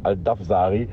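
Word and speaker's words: Al-Dawsari: Also ich meine, die ganze Welt redet über Lionel Messi, aber vielleicht sollte sie auch mal über die, die Kollegen Al-Sheri und Al-Dawazari reden. Habe ich die jetzt richtig ausgesprochen? – Nee, Al-Dafsari.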